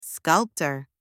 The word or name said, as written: sculptor